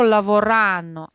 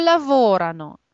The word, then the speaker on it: lavorano: Когда вы будете говорить про местоимение «они», помните, что ударение у вас с предпоследней гласной перемещается чаще всего на первую или на вторую гласную. То есть в данном случае вы говорите не loro lavorano, а loro lavorano.